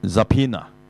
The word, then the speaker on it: subpoena: 原來咧佢就咁叫 subpoena。 – subpoena 個 O 字又唔知去邊嘅，冇發音嘅。咁 subpoena 點解咧？其實 subpoena 就係法庭傳召咁解啊。即係話咧嚇，法官決定咗要你召你嚟作證啊，點點點就叫 subpoena 啦。